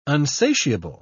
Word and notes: unsatiable: British English

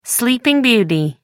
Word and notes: sleeping beauty: American English

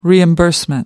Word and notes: reimbursement: British English

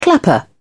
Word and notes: clapper: British English

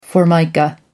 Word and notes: formica: American English

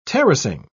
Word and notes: terracing: British English

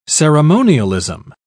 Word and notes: ceremonialism: British English

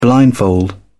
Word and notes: blindfold: British English

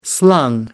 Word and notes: slung: American English

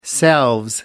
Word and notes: selves: American English

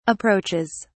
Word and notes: approaches: British English

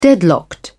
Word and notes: deadlocked: British English

